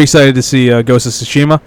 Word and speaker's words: Tsushima: Yep, Ghost of Tsushima.